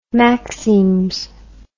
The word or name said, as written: maxims